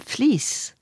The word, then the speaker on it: vlees: sous V-L-E-E-S, ça va se prononcer quelque chose comme fleece et qui correspond à flesh, la chair en anglais.